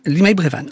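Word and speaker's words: Limeil-Brévannes: Limeil Brévannes, c'est ça? – Limeil Brévannes. – J'ai bien prononcé, c'est déjà pas mal. – Avec un S à la fin. – Oui, qui ne se prononce pas puisqu'il est à la fin du mot.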